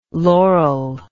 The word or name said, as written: loral